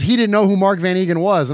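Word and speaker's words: van Eeghen: Also, so in that soundbite with Alec Ingold there, he called him Mark Van Egan. And Van Egan is what we were always, the way we pronounced it growing up.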